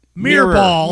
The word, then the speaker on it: Mirrorball: M-I-R-R. – Oh, okay. Because you were pronouncing like M-E-E-R. – Mirrorball. – Mirrorball. – Mirror. – Mirrorball. – Yes, please enunciate.